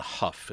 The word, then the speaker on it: Hough: All right, so this film was directed by, I'm going to say John Huff is how we pronounce that. John Huff, who spent the 60s. – Not John Ho? – I don't think so, because he's British.